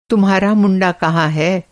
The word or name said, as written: मुंडा